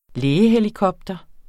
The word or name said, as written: lægehelikopter